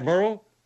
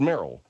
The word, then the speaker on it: Meryl: Is that Merle? – Merle? – Well, that's the one that they were pointing out to me. – The name's pronounced Meryl.